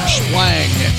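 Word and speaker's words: Shplang: Herb Splang, S-H-P-L-A-N-G. I didn't mispronounce it, I didn't stutter, and your ears didn't flap.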